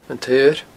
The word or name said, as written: antsaoir